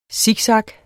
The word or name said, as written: siksak